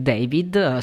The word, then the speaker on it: D4VD: Questo artista si chiama David, ma già il suo nome è scritto in maniera D4VD, che si legge David, secondo quanto ha raccontato lui alla stampa,